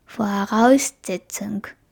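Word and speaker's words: Voraussetzung: フォアラウスゼツンク。これは真ん中のアウスっていうのにアクセントがあるので違います。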